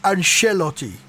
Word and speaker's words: Ancelotti: I think you'll find it's pronounced Ancelotti.